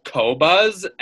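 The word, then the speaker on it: Qobuz: There's a thing, I think it's a French thing, and it's pronounced Cobuzz, and that's another music distributor.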